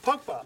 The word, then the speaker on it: Pogba: Pogba.